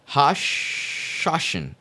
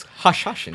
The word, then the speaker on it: Hashashin: Hashashin. Idiot. It's Hashashin. – I would just die for saying it wrong. I wouldn't even be entered in the fucking room. – It's Hashashin.